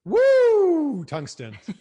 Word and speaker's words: Woo Tungsten: So the correct way to pronounce this, and you'll like this, is Woo Tungsten.